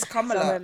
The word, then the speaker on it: Kamala: I'm telling you the proper pronunciation, okay. Isn't it? It's Kamala.